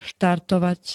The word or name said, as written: štartovať